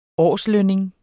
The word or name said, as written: årslønning